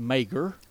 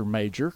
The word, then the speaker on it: Mager: So her name is really pronounced Mager. – Right. – But everybody calls her Mager because it's spelled M-A-G-E-R.